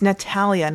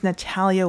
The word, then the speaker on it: Natalia: It's Natalia, not Natalie. My name's Natalia Winter, not Natalie Winter.